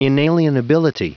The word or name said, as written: inalienability